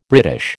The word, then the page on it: British: American English